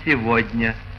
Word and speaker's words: сегодня: Отсюда и в слове «сегодня» «г» произносится за «в».